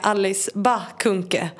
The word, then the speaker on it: Alice Bah Kuhnke: Som ni nu har övat på hur jag ska uttala. – Det lät perfekt. – Ja, nu ser jag. Jag har alltid sagt bah, men nu får jag ändra det.